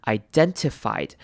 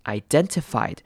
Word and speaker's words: identified: Identify 这个字要注意的是前面开头的 i 要念长母音 i 的声音 identified。那这边的 t 你可以省略，或者是你可以把它照常念出来。你可以念成 identified 或者是 identified 都可以，两种声音美国人都听得懂。